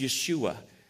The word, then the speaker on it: Yeshua: which would have been Joshua, or pronounced Yeshua.